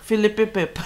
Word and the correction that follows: Pirrip: Philip Pirrip.